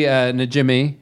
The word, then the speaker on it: Najimy: Seth Green, John Lovitz, Kathy Najimy, right? – Yeah.